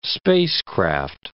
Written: spacecraft

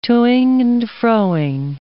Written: to-ing and fro-ing

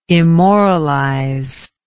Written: immoralize

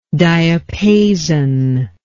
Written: diapasonal

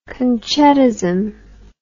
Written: concettism

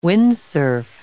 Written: windsurf

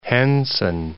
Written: Henson

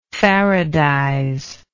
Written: faradize

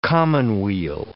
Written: commonweal

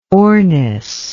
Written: ornis

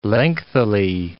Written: lengthily

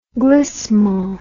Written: glissement

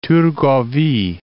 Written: Thurgovie

